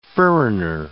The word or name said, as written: furriner